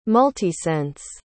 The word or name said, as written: multisense